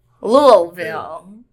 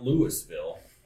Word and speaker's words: Louisville: Can't say it that way, though. – Louisville. The second Louisville. L's are important. – Louisville. I do it right. – The S is silent. – There's a lot of silent letters and some extra. – And some extra. – I found that out. You get corrected by people. – Yeah. – It is not Louisville.